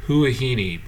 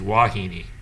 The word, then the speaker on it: Huahine: So when you look at on the map, and my terrible Polynesian pronunciation, I would have said Huahine, but if you say the correct pronunciation is Wahine. It starts with an H.